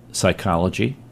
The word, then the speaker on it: psychology: Psychology, P is silent.